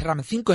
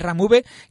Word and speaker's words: Ram V: Por ejemplo, Ram V, que ya me han dicho que V es un apellido, no es un número, no es Ram 5, es Ram V,